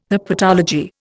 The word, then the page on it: herpetology: Indian